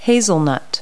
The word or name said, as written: hazelnut